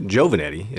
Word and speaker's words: Giovannetti: And, you know, I still have family from New York, where I'm from originally, and the name is pronounced Giovannetti, is how it's supposed to be pronounced. But when you live in West Texas, you say Robert Giovannetti.